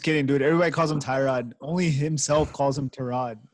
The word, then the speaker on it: Tyrod: No, Tyrod. That's how he pronounces his Tyrod.